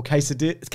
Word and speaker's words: quesadillas: I'd recommend the chicken pineapple quesadillas. How do you say it? – Quesadillas.